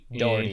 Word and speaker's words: Dougherty: I, the way it looks, uh, I believe it's directed by Michael uh, Dougherty. I believe it's how it's pronounced. He did Trick or Treat. – Dougherty, he did I an, yeah.